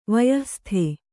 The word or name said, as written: ವಯಃಸ್ಥ